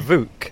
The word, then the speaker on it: Vuk: Be careful how you say it, but it's Vuk. V-U-K. – Yeah, this morning everyone had only ever seen that name written down, and much debate over pronunciation. Everyone very relieved to discover it was a ooh sound.